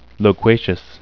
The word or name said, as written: loquacious